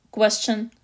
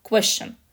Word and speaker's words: question: Есть в английском языке слово «вопрос», question, и до какого-то момента я была абсолютно уверена, что правильное произношение этого слова question. Ну что там в серединке, пару согласных, все это вместе, в быстром темпе дает звук «щ». Вот он и был question — Потом я поступаю в языковой лицей, где мы более четко изучаем английский язык.